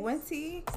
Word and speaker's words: Wente: You think that's how Wente, you pronounce Wente? – It? – Wente. Sounds about right.